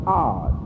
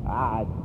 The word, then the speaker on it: Ad: Now the word O-D-D is pronounced Ard, right? This is pronounced Ard. Ad. Correct pronunciation is Ad.